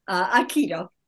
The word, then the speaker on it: Aikido: So you have a lovely technique called the interpersonal Aikido technique. Have I pronounced that correctly? – Aikido.